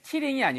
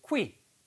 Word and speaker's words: quitting: 발음이 Q 발음이요. Q, Q, quit, quit. 이렇게 발음하셔야 돼요. killing이 아니고 quit, quit. 입술이 쭉 나왔다가, 군무원처럼 like goldfish.